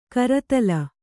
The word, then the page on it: ಕರತಲ: karatala